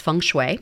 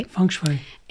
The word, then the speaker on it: feng shui: It's actually pronounced feng shui. – Feng shui.